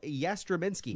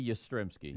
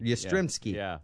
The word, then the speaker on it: Yastrzemski: And Carl Yastrzemski. – No. – Did I say that wrong? – It'd be Yastrzemski. – Yastrzemski is 78.